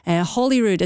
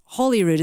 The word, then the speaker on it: Holyrood: Holyrood as in holy, not Holyrood as in holly tree, so Holyrood.